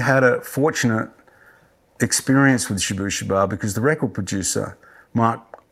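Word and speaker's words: Shabooh Shoobah: Was that with Shabu Shabu or was it later? – With Shabu Shaba, that's how you pronounce that.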